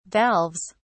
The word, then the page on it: valves: British English